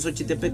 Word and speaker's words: Xochitepec: Entonces mañana en la presentación allá en Cuernavaca, no en Xochitepec, donde jugaban los colibríes.